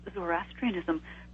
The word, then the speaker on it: Zoroastrianism: You actually pronounced Zoroastrianism correctly.